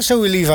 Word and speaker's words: Zoe Levi: En dan gaan we naar Zoe Levi. Zeg ik dat goed? – Ja, Zoe Levi.